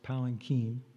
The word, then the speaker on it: palanquin: By the way, the word is pronounced palanquin, anyway.